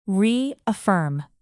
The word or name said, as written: reaffirm